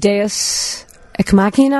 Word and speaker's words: Deus Ex Machina: And something that makes me crazy is Deus Ex Machina, am I saying it right? – Yeah, sure.